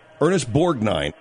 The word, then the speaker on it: Ernest Borgnine: Ernest Borgnine. – Ernest Borgnine. – Ernest Borgnine.